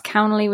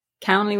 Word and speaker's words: Cownley: That's Cownley with an N. I cannot tell you how many times my last name has been butchered. I've been called all sorts. I really have. Cownley with an N.